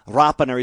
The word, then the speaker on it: Rapana: Maybe we didn't, but that's what Jordan wants. He's been Rapana for so long, but always a niggle in the back of the mind that Rapana is the actual pronunciation, and, mm-hmm. and he's never been overly strong in enforcing that. But we pinned him down before round one, and he said, let's go with Rapana.